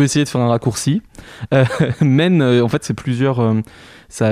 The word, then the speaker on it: Maine: Alors d'abord, Men, M-A-I-N-E, comme la rivière. Est-ce que j'ai bien prononcé déjà?